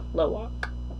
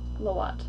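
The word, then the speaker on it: Lo'ak: Loak. – Loat. – No, it's K. – L-O-A-T.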